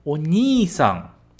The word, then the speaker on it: Onii-san: Uma dica de pronúncia, sempre lembra de prolongar. Fechado? Onii-san. Onii-san, irmão mais velho.